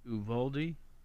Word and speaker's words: Uvalde: How do you say it? Uvalde, Texas? – Mm-hmm. Yeah. Pretty sure that's it.